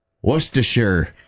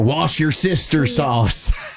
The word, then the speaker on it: Worcestershire: Worcestershire. Or wash your sister sauce.